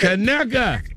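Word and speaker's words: Conecuh: Kaneka. That is how you say it.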